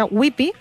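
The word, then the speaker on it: Ypi: L'autora, espero dir-ho bé, es diu Lea Whippy, perquè es pronuncia Whippy, edita Anagrama, i la traducció és de Cecilia Ceriani.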